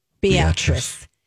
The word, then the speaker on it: Beatrice: Spelled Beatrice. – Pronounced Beatrice.